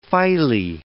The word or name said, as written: phyle